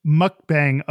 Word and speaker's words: mukbang: Well, also today, we're going to talk about mukbang or mukbang. – Mukbang. Oh, I didn't know the pronunciation. Mukbang. – Yeah. Mukbang.